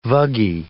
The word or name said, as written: vuggy